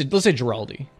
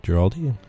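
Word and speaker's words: Guaraldi: Let's say Giraldi. – Giraldi.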